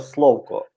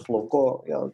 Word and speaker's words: Словко: Я називав це Словко, але ну я не проти, як хтось каже Словко. Я думаю, що тут слово гнучке в наголосах.